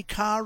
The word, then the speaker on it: Cara: It's Cara.